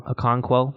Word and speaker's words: Okonkwo: And Okonkwo, that's how you say it, had 1 catch.